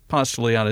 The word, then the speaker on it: Ponce de Leon: Ponce de Leon is how we say it in Atlanta, not with the proper Spanish pronunciation, but there is in Atlanta, of course, Ponce de Leon Avenue, where one part of the church was originally located.